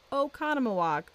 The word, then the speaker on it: Oconomowoc: we definitely learned the correct way to say Oconomowoc.